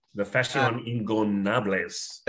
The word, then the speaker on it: La Facción Ingobernables: AEW put on a tornado tag match between La Facción. I can never say it right. – La Facción Ingobernables.